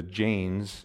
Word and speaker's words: Jain: For those of you who study world religions, the Jains, it's spelled J-A-I-N, but it's pronounced Jain as in J-A-N-E.